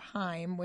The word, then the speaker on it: Haim: It's a group of sisters, and they go by the name of Haim, which there's a lot of discrepancy on how to pronounce that, so I think we've got it right. We read somewhere that it rhymes with time per their manager spokesperson.